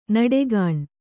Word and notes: ನಡೆಗಾಣ್: Kannada

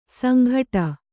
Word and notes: ಸಂಘಟ: sanghaṭa

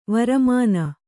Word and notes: ವರಮಾನ: varamāna

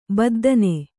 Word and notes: ಬದ್ದನೆ: baddane

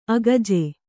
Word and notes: ಅಕ್ಕಜ: akkaja

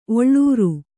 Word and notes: ಒಳ್ಳೂರ್: Kannada

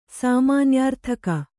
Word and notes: ಸಾಮಾನ್ಯಾರ್ಥಕ: sāmānyārthaka

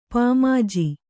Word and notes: ಪಾಮಾಜಿ: pāmāji

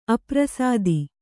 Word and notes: ಅಪ್ರಸಾದಿ: aprasādi

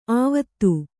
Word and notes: ಅವತ್ತು: Kannada

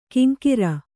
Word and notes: ಕಿಂಕಿರ: Kannada

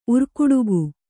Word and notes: ಉರ್ಕುಡುಗು: kannada

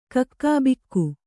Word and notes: ಕಕ್ಕುಬಿಕ್ಕು: Kannada

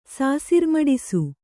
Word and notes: ಸಾಸಿರ್ಮಡಿಸು: Kannada

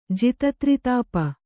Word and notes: ಜಿತತ್ರಿತಾಪ: jita tritāpa

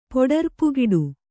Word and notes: ಪೊಡರ್ಪುಗಿಡು: kannada

